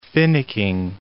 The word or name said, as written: finicking